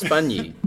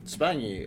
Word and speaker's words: Spagni: It's pronounced Spagni. – Spagni. – You know, like the GN and lasagna.